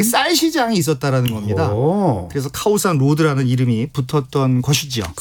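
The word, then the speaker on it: 카오산 (ข้าวสาร): 그래서 이제 카오산 이렇게 발음을 해야 알아듣고요.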